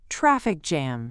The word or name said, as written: traffic jam